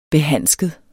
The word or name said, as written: behandsket